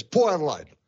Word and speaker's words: Port Adelaide: Over here in Victoria, we might say Port Adelaide, but the local pronunciation is Port Adelaide. – Adelaide. There's no T. There are zero T's in Port Adelaide. – Poor and A become one vowel sound.